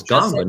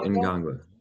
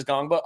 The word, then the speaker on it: Ngongba: And I was like, it's Ngongba, not Ngongba.